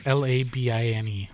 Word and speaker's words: Labine: Taylor L-I-B-I-N. Labine. Fuck, that's not even how you spell it. I'm an asshole. Shit, did I say Taylor? I think his name is Tyler. Fuck. I even wrote it down and I fucked it up. That's Dizzy. – Labine. – Hold on. He's Canadian, so it's spelled stupid. Yeah, it's Tyler Labine.